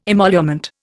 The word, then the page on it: emolument: Indian